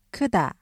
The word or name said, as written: 크다